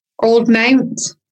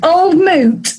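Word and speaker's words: Old Mout: It does, it says Old Moot rhymes with fruit.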